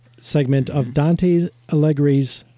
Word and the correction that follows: Alighieri: Uh, how do you say that? Alle- I think it's Alighieri.